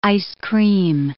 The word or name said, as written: ice cream